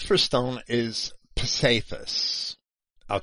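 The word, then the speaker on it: psephos: Cephas is P-S-E-P-H-O-S. I say Cephas because the E is an eta and not an epsilon. So I would pronounce that as an A-Y.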